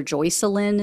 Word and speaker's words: Joycelyn: His wife's name is Jocelyn, or Joycelyn, is how you pronounce it. It's spelled Joyce and then Lynn.